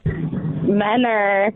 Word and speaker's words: Mentor: Menner. – And that's two N's? – Well, I pronounce it as if it has two N's. I know it's spelled with a T. – Right. – But if we know exactly how Morgan said, M-E-N-N-E-R,